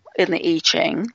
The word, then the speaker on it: I Ching: If you're interested in the I Ching, it's the letter I, pronounced E, and then Ching, C-H-I-N-G.